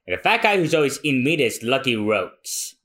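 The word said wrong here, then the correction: Roux: Lucky Roo. It's French.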